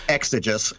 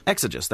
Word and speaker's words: Exiguus: So according to mainstream history, in the 6th century, there was a Christian monk named Dionysius Exiguus, or however you pronounce that. – Exiguus. – Exiguus. There we go.